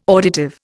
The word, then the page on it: auditive: Indian